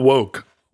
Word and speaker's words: Wouk: no, no, not Woke. No, he is Walk. no, it, it is pronounced woke.